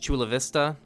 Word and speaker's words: Chula Vista: This is just another one, pretty much Chula Vista, which I hope I said that correctly.